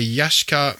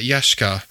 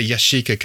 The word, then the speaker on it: Yashica: And yes, I pronounce it Yashica, not Yashica. I don't know where Yashica came from, but certainly all the Japanese videos I've ever watched, they say Yashica. So that's what I say. So it's a Yashica Minitec.